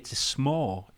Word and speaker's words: s'more: I think I heard Sean Connery saying it, so I was calling it a s'more. And she kept laughing at me saying it. I was like, wait, why am I saying it wrong? She says, yeah, it's a s'more. It's because you always want some more, s'more.